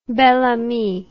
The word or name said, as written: belle amie